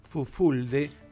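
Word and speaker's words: Fulfulde: Fufulde o Fufuldi. – Exactamente. – Así se pronuncia en inglés. – Ese es el nombre correcto, Fufuldi, la forma correcta de expresarlo, no lo sabía.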